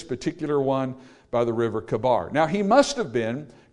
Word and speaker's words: Chebar: Now in Ezekiel chapter 1, in verse 1, we're told that Ezekiel is there with the captives by the river Kabar, sometimes pronounced Chebar, but they're there by this river.